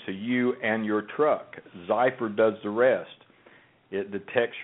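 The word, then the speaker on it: Xypper: You just need to download the Zyper app to your smartphone. And let me spell that. It's X-Y-P-P-E-R, Zyper.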